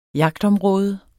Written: jagtområde

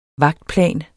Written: vagtplan

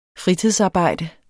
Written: fritidsarbejde

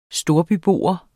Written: storbybo

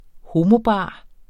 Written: homobar

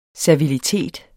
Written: servilitet

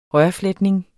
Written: rørfletning

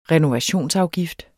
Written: renovationsafgift

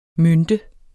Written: mønte

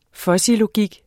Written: fuzzylogik

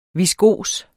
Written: viskos

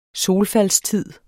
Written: solfaldstid

